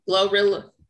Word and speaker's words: GloRilla: Glorilla.